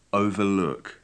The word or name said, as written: overlock